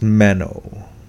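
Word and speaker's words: meno (μένω): So yeah, so it's pronounced meno. Meno.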